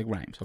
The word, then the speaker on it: Grimes: Se dice Grimes.